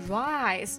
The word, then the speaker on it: rice: 其实我刚读的是 rice，就是我们说的米饭呀，是 s 结尾的，它的发音啊。